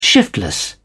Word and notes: shiftless: British English

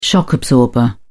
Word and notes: shock absorber: British English

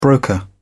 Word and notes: broker: British English

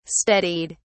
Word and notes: steadied: British English

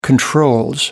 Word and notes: controls: British English

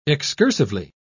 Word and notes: excursively: British English

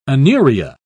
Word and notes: anuria: British English

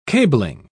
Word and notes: cabling: British English

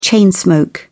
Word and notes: chain smoke: British English

